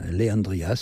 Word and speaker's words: LeAndria: Alors, Leandria Johnson, Leandria, ça s'écrit L-E-apostrophe et Andria, est une artiste de gospel encore jeune.